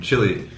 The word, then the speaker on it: Chile: Chilly, and that's how we pronounce the country, Chile, in English.